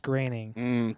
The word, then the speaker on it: Groening: Well, it's spelled Groening, but it's pronounced Groening.